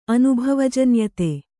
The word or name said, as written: ಅನುಭವಜನ್ಯತೆ